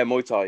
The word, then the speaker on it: Muay Thai: Muay Thai? – Yeah, Muay Thai, yeah.